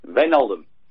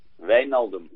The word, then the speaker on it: Wijnaldum: Pronuncia otra vez Wijnaldum. – Wijnaldum. Wijnaldum. – Perfecto, pues ya sabes cómo es.